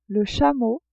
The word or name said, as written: chameau